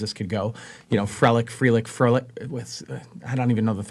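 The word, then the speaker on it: Frelick: Am I saying his name right? – It's Frelich. Yes.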